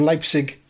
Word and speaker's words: Leipzig: And followed by any episode that contains a pronunciation of Leipzig by DJ Beans.